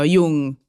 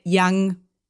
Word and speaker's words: Jung: Jung. Es Jung. En alemán, así que está muy bien. La gente le dice Yang porque lo dice como en inglés, pero es Jung, así que perfecto.